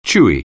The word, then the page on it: chewy: English